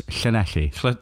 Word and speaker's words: Llanelli: Well done on the pronunciation. – Llanelli. – Yeah, whatever. – Was that all right? – Yeah, it was. It's Llanelli.